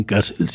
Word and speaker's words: Kassel: Genios de Kassel. – En Kassel, sí.